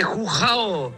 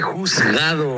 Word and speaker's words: juzgado: Oye, chiquillo, no se dice jujao. se dice juzgado.